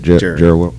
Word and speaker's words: Jerry World: Correct. It's at Jerry World. – Yeah. – That's how you say it. Jerry World.